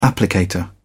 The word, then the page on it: applicator: British English